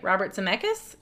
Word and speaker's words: Robert Zemeckis: So not only the actors, but for approximately 3 weeks, Robert Zemeckis, I don't know if I'm saying that right, Robert Zemeckis? – Yeah, that sounds right.